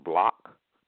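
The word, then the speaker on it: blæc: Go to any dictionary that has an etymology, and it'll say in there bleached or black, spelled B-L-A-E-C, pronounced block.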